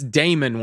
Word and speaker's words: Damon: Yes, Damian Wayne was the comedian of the Wayne's brothers. – I think that's Damon Wayne. – Damon Wayne. Okay.